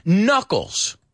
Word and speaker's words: knuckles: Well, this one has a silent K too. And this is knuckles.